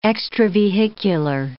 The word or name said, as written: extravehicular